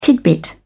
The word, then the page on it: tidbit: British English